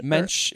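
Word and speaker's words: Moench: It's M-O-E-N-C-H. – Is it Mensch? – Mensch. Or, is okay. that what it is? – Yeah. – Or I always said Monch, but I don't know.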